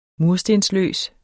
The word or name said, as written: murstensløs